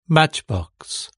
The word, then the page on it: matchbox: British English